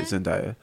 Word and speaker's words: Zendaya: Right, Zendaya.